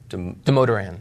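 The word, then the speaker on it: Damodaran: Professor, how do you say this name? We never know how to say it. Professor Demoderan. – Demoderan? – Yep.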